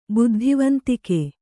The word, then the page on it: ಬುದ್ಧಿವಂತಿಕೆ: buddhivantike